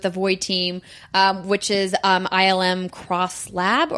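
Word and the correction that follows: ILM xLAB: or I think it's just ILM X Lab is how you say it actually.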